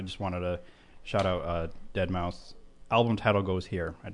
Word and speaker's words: Deadmau5: Deadmau5.